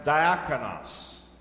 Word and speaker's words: diakonos: And folks, this word service, the word that we translate service in the Greek, is the title of this message, and it is pronounced diakonos, deacon, servant.